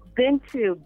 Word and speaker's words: Bintu: This is Bintu. – What's your name? – I'm calling from Bintu. – Wait, say your name again. – Bintu, B-I-N-T-U.